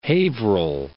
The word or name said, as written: Haverhill